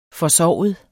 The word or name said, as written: forsovet